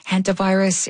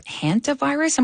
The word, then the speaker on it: hantavirus: It's the first I've ever heard of hantavirus. Am I saying that correctly? I mean, just tell us about it and exactly how people get it. – Well, yes, you are saying it correctly, and you are correct in assuming that it's not something you hear about very often.